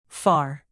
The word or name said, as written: far